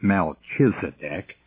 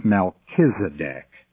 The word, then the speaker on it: Melchizedek: It is not Melchizedek, because in Hebrew, the CH sound is pronounced as a K, so it's Melchizedek.